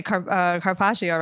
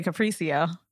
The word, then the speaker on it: carpaccio: I didn't used to say carpaccio, right? I used to say capriccio.